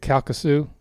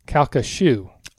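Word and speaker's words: Calcasieu: She said, it's actually pronounced Kalkashoo. – Oh. – So we didn't. – So we almost had it. – Yeah. – But it's shoe instead of shoe.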